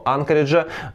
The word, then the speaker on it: Анкоридж: в сторону Анкориджа. Я, кстати, удивился, но правильное ударение именно Анкоридж. То есть допускается и Анкоридж, и Анкоридж, но правильно Анкоридж.